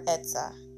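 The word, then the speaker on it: etzah (עצה): Like, when it says, but it is the counsel of Yah that stands, in the Hebrew, that word for counsel is the word etzah. And it is spelled with an ayin, a zadi, and a hey.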